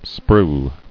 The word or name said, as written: Sprue